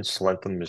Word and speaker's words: slenthem: and please correct my pronunciation if it is wrong, a slenthem? – Yeah, from what I've seen, slenthem is the way that the English pronounce it.